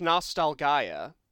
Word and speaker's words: Nostalgaia: It's pronounced Nostalgaia.